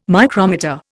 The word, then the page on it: micrometer: Indian